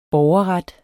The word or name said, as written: BORGERRET